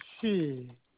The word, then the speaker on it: Shi: I'm sorry, S-H-I. – Yes, S-H-I. It's pronounced she.